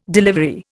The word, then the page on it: delivery: Indian